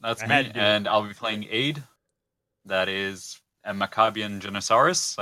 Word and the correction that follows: Janissary: Janissary. – Janissary. – Janissary? All right. I think I did that right.